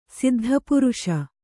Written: ಸಿದ್ಧಪುರುಷ

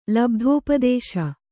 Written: ಲಬ್ಧೋಪದೇಶ

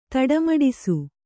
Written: ತಡಮಡಿಸು

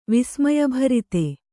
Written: ವಿಸ್ಮಯಭರಿತ

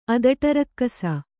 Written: ಅದಟರಕ್ಕಸ

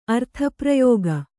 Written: ಅರ್ಥಪ್ರಯೋಗ

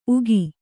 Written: ಉಗಿ

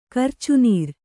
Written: ಕರ್ಚುನೀರ್

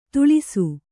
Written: ದಳ್ಳಿಸು

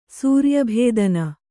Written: ಸೂರ್ಯಭೇದನ

